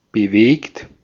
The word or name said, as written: bewegt